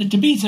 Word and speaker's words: Tapeats: The Tapeats, as it's called. – Oh, Tapeats, I'm sorry. – A little different pronunciation, but that's okay.